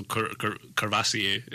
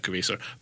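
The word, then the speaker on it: Courvoisier: So Cervasier is like... – Cervasier. – Cervasier, yeah. Sorry, I pronounced that Curvesor.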